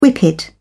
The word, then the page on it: whippet: British English